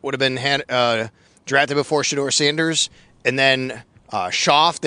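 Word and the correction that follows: Shough: is that how you say his name? I think the quarterback, right? I always do this wrong. – Shuck, for some reason, despite how it's spelled. – Yeah, right, Shuck.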